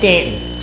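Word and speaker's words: Staunton: Or as you say it correctly, Stanton. Sorry, I didn't put enough "and" in Stanton.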